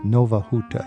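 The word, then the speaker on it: Nowa Huta: Nova Huta. Am I saying that correctly, Beata? – That is correct.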